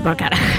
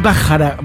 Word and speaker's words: Bacharach: Para mí es Bart Bacharach. Pero hay gente que es Bacharach, ¿viste? – no.